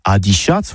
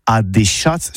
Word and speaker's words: adichats: Eh oui, adicias, que c'est prononcé au tabé adichats, voire adéchats, surtout en Gascogne toulousaine.